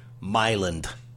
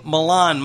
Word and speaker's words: Milan: People who pronounce the town in Illinois, Myland. – With a D? – You ever hear that with a D? – Myland. – Yeah. – Myland. – Yeah, well, we went over to the bowling alley in Myland over there. – Yeah, they do say that. – What? There's no D.